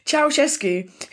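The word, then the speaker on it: Ceaușescu: Okay, linking in nicely, next we've got Ceausescu. – Beautifully pronounced. – Thank you very much. I didn't even have to Google it before I said it. Ceausescu, who's the brutal former president of Romania, and his wife are executed by firing squad on Christmas Day 1989.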